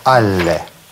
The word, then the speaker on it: alle: Мы используем сочетание предлога «а» и артикля «ле», которые вместе произносятся как «алле».